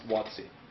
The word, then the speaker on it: WotC: Yes, their Wizards of the Coast acronym is generally pronounced WotC.